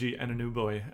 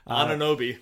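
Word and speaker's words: Anunoby: Anunobi.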